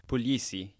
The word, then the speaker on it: Puglisi: And as part of that episode, they talked to Christian Puglisi. Is that how you pronounce it? – Puglisi, yeah.